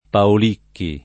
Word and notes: Paolicchi: lingua italiana

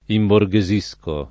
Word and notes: imborghesire: italiana

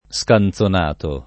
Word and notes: scanzonato: lingua italiana

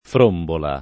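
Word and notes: frombola: italiano

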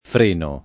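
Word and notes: frenare: italiano

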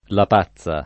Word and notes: lapazza: lingua italiana

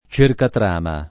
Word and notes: cercatrama: lingua italiana

